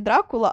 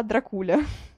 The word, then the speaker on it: Дракула: В общем-то, ладно, мы отвлеклись, это уже просто интересный фан-факт, что правильно говорить не Дракула, а Дракуля.